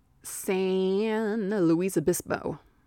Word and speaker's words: San Luis Obispo: He's from Cal Poly San Luis Obispo. That's how you say it.